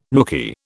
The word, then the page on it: nooky: British English